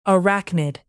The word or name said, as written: arachnid